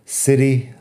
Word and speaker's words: city: City, la T es como suave como una D. Eso es típico en inglés americano.